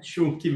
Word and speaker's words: Schunk: Depois ele vai falar se eu estou pronunciando corretamente o nome dele, se é Chunk ou se é Chunk. É Chunk mesmo.